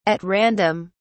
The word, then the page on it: at random: British English